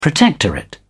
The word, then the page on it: protectorate: British English